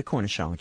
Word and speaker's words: cornichon: It's spelled C-O-R-N-I-C-H-O-N.